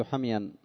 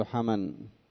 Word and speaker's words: حمي: فإذا وجد الإنسان حرارة ضيقا من سبب الحرارة، يقول الحريري: يقول أجد حميا أو حموا، ولا يقول أجد حما. أكثر الناس يقولون حما، دنيا حما، والحريري يقول يقال حمي وحمو مثل ظبي ودلو. كما تنطق بظبي تقول حمي، وكما تنطق بدلو تقول حمو.